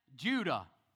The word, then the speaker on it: Judah: But then Judah, which rhymes with Judas, they said, no, let's sell him.